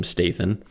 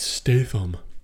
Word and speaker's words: Statham: Jason Statham. You pronounced his name wrong. It's Statham.